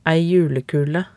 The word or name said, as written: ei julekule